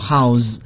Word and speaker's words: house: 其实名词也是这个 house，对，但是做名词时它的发音 house，可是作为动词变作动词之后呢，要读作 house。这就是我们所说的这个名词转为动词之后它词尾的发音要发生变化。